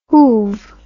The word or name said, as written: whove